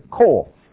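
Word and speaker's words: kol (קול): This is kof, that's kaf. But it's still pronounced kol.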